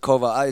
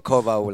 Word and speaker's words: Koval: I pronounce it Koval. It's been butchered so many times. always, but Koval like a V.